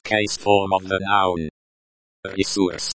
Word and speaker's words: ресурс: Pronounced resource, emphasis on sore.